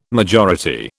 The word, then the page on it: majority: British English